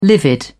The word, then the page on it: livid: British English